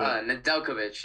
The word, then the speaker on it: Nedeljkovic: But Nedeljkovic. I don't know how you pronounce it. – Nedeljkovic, yeah, Alex Nedeljkovic.